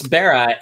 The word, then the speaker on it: Bara: But it's Bara, and it's actually a Hebrew word which means creation.